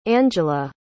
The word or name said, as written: angela